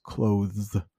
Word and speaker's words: clothes: It's pronounced clothes, clothes. Not easy. So again, Americans, or probably the British too, Canadians, we just find easier ways to pronounce something. And I think instead of looking at that TH there, let's just make it an S.